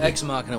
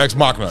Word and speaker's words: Ex Machina: Ex Machina was really Ex Machina. Good. Yeah, sorry, I said it wrong.